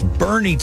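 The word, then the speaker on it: Boerne: Only one Texas city made that list, and it's Bernie, Texas, which is spelled kind of funny.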